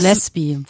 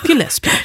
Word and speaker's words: Gillespie: Das Gillespie wird der Name in der Regel ausgesprochen. Warum weißt du sowas? Weiß ich nicht. Du machst mir Angst. Okay, Gillespie.